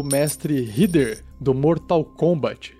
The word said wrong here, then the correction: Raiden: É o Raiden. – Ah, é Raiden?